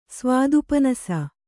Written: ಸ್ವಾದುಪನಸ

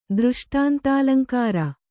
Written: ದೃಷ್ಟಾಂತಾಲಂಕಾರ

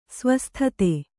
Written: ಸ್ವಸ್ಥತೆ

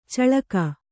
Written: ಚಿಲಕೆ

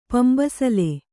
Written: ಪಂಬಸಲೆ

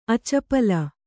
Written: ಅಚಪಲ

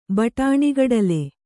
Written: ಬಟಾಣಿಗಡಲೆ

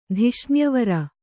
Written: ಧಿಷ್ಣ್ಯವರ

